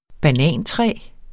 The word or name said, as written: banantræ